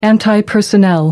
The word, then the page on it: anti personnel: American English